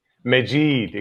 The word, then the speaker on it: Majid: Majid. Exactly how it's written.